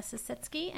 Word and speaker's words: Sisitsky: Again, my name is Dana Sisitsky, and my email address is dsisitsky.